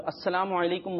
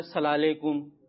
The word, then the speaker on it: السلام علیکم: ہمیں درست تلفظ کے ساتھ بولنا ہے۔ بعض بچے کیا کرتے ہیں، کوئی بول رہا ہوتا ہے سلام علیکم،